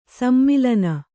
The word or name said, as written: ಸಮ್ಮೇಲನ